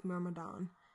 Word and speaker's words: Myrmidon: So anyways, with the we're going to call it the old helm and the new helm, because I seem to be having a problem with Myrmidon. Oh, wow. There we go. – Yeah, and you said it correctly.